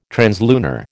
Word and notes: translunar: American English

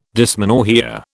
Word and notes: dysmenorrhoea: British English

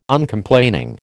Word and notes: Uncomplaining: American English

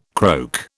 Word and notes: croak: British English

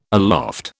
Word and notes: aloft: British English

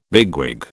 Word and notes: bigwig: British English

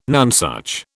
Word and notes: nonesuch: British English

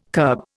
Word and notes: curb: Indian

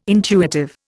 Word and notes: intuitive: Indian

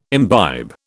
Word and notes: imbibe: British English

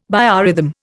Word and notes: biorhythm: Indian

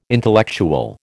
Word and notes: intellectual: American English

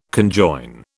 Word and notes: conjoin: British English

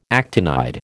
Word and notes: actinide: American English

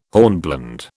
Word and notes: hornblende: British English

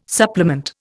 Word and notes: supplement: Indian

